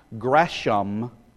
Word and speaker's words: Gresham: That's the way you do pronounce that name. I'm not having a funny lisp or something. It isn't pronounced Gresham, just so you think I'm lisping or something like that. It's Gresham Machen.